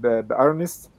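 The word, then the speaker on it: إرنست: فبرحب بأرنست وأنا بقولها بالنطق الإنجليزي، وصبحي، وأهلا بيكم.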